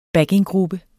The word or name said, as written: backinggruppe